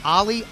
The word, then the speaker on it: Olli: And I guess it's pronounced Olli, O-L-L-I, to campus.